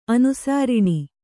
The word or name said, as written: ಅನುಸಾರಿಣಿ